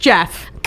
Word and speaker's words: Gef: G-E-O-F-F? – Mm-mm. – Oh. – G-E-F. – Oh, Geff. – Pronounced Jeff. – God damn it. – Pronounced no. – Jeff. – It's Jeff. J-E-F-F.